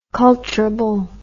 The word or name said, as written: culturable